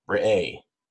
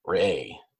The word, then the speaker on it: Re'eh: So this week is Parshat in Deuteronomy is called Re'eh. That's spelled R-E apostrophe E-H. Re'eh.